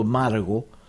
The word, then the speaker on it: Margo: Ni siquiera Margot, dicen, ha llorado tanto Margo que dan ganas de llorar. O sea que fue tal cual, Margo. Margo es el, la forma de pronunciarlo.